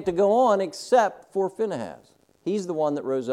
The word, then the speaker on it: Phinehas: Now, my point that I wanted to go to this passage for is, okay, Phinehas or Phineas, however you want to say his name, but by the pronunciation here, it's Phinehas,